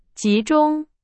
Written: 集中

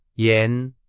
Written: 严

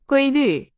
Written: 规律

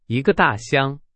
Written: 箱

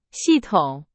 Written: 系统

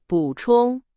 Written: 补充

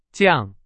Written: 降

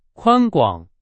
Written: 宽广

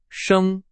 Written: 升

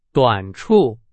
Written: 短处

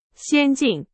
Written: 先进

